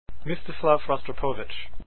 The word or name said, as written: rostropovich